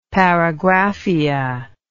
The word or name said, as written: paragraphia